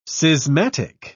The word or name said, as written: schismatic